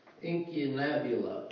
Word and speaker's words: Incunabula: Incunabula.